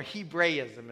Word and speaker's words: Hebraism: This is a Hebrewism or a Hebraism is the right way to say it.